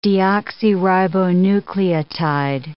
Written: deoxyribonucleotide

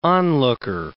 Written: onlooker

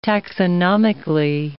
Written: taxonomically

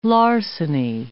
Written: larceny

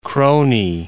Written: krooni

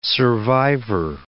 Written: survivor

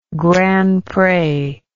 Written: Grand Pre

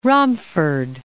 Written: Romford

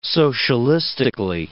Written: socialistically

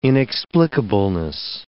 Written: inexplicableness

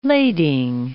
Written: lading

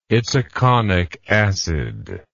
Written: itaconic acid

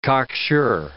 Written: cocksure

